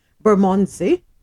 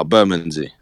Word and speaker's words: Bermondsey: Am I saying that right? Bermondsey on Monday? – Nah, Bermondsey.